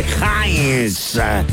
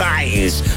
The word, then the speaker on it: Heinz: Sí, vamos a hablarles de Heinz. Así se pronuncia, mi gente querida, por si acaso. Heinz.